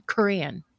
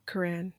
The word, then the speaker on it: Quran: Quran, is that how you say it? Quran or Quran? Quran. – Quran. – Yeah.